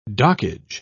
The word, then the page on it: dockage: British English